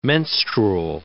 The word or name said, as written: menstrual